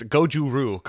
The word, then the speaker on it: goju-ryu: No, it's it's goju-ryu karate. – Goju-ryu. – That wasn't close. I was giving you the. But thank you. Three syllables.